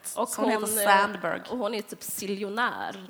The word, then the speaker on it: Sandberg: galen feminist som heter Sheryl Sandberg, eller Sandberg om man uttalar på svenska.